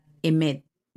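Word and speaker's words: Emet (אמת): Presten atención, la palabra verdad en hebreo está compuesta de la primera, la del medio y la última letra del alfabeto, Aleph, Mem, Tab, se pronuncia Emet.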